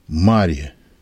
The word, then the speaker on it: Мария: Вы заметили, преподобную Марию египетскую именуют Мария египетская, ударение на первом слоге.